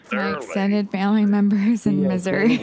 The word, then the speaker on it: Missouri: It is misery. – From St. Louis, misery.